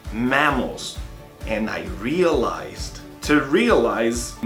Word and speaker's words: mammals: Mammals são mamíferos. Observe que a pronúncia dele também é um pouco diferente. É mais aberto pro mammals.